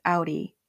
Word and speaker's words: Aury: It's Reborn with Audi, and Audi is spelled A-U-R-Y.